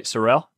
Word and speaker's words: Sorrell: And I said that right, Sorrell? – Yeah.